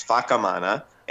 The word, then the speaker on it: Whakamana: in Maori, the WH makes the sound of an F. So it's spelled Whakamana, but it's Whakamana.